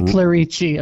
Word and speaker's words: Clerici: Say Clerici. It sounds better. – Yeah, Clerici. You're right.